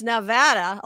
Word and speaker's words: Nevada: People always pronounce it wrong. For those who don't, it's Nevada, like banana.